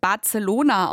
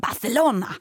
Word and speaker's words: Barcelona: Und damit wir alle mal endlich lernen, dass es nicht Barcelona ausgesprochen wird, sondern Barcelona, haben Sophie Tucker ihren Song, der so heißt, mit TH geschrieben. Also B-A-R, ne, wie Bar, dann T-H. Elona, also Barcelona.